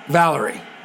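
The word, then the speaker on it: Valery: Pronounce Valerie, like the Steve Winwood song or the Amy Winehouse song.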